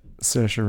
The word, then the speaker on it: Saoirse: Can you pronounce the actress's name yet? – Yeah, Saoirse Ronan. – Hey, nice. Okay. Yeah. – That's a tough one.